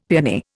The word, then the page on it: peony: Indian